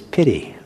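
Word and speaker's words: pīti: So the word is, unfortunately, what it sounds like in English, the word is pity. Pity. It's spelled P-I-T-I.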